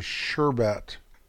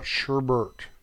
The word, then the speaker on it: sherbet: For a director's correction here, the pronunciation of that frozen treat is sherbet, not sherbert.